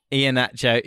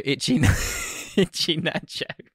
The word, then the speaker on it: Iheanacho: So Caballero, Calabro, Iheanacho, Itchy Nacho.